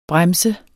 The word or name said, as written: Bremsen